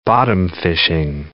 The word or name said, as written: bottom-fishing